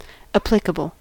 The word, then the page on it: applicable: American English